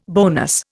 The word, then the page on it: bonus: Indian